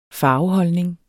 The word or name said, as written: farveholdning